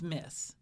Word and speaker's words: Myss: And he had been at a workshop of Carolyn Mace's. – Mace. – Yeah, it's really a hard name to pronounce. – I think it's Mace. – I think it's Miss. – Okay. – It's spelled M-Y-S-S.